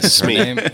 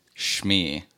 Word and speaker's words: Smee: Smee. – Wait. Okay. So my wife always corrects me if I say Shmee. Because she says that's not how you say it.